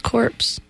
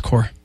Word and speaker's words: corps: It's his corpse. – Yeah, I know. It's core. – I'm reading verbatim. – That's okay. You can do whatever you want. – If it's spelled wrong, I'm going to read it that way. – It's not spelled wrong. That's how you spell it. – Really? – Yeah. – The P is there. – It's silent. – And it's silent. – That's core.